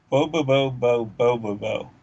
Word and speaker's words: Bobobo-bo Bo-bobo: BoBoBo-Bo-BoBo, right? – Yes.